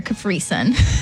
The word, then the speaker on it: Capri Sun: I can't wait for you to go back and hear Capri Sun. – I can't wait to see it. – Have you never heard of 635-32? – I don't see how I said it wrong.